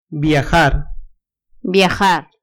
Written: viajar

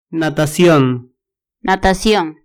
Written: natacion

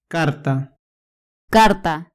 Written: carta

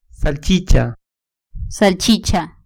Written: salchicha

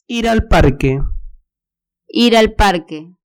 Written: ir al parque

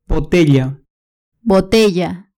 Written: botella

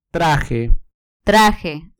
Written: traje

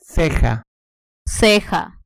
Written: ceja

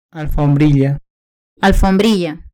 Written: alfombrilla

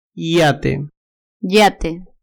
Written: yate

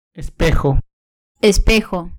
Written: espejo

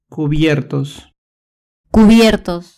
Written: cubiertos

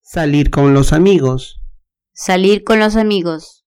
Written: salir con los amigos

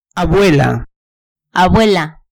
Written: abuela

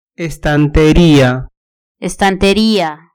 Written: estanteria

